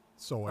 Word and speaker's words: Sooy: Eric, you know, Eric, uh, is it Sooy? Is that how you pronounce his last name? – soy, uh, like soy. the sauce, soy.